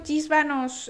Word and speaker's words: Gisbanos: Pero no dijo Gisbanos eh, el otro día, Gisbanos, con J que me lo dijo. Eh, o sea, escribí con G, pero se pronuncia con J.